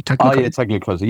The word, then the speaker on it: Tagliacozzi: Tagliacozzi.